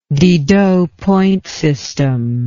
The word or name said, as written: Didot point system